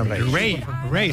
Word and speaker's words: Raye: Ray. – Se escribe Raye. Raye, con Raye, Raye. Raye.